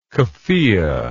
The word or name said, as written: kufiyeh